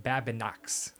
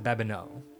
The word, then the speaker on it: Babineau: It looks like Babinox. Babinukes. – Yes, that's correct. – But it's not. It's Babineau.